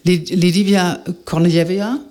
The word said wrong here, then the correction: Lidia Kornejewa: so sprichst du es mal richtig aus. – Lidia Kornejewa, ne? – Das sag ich doch.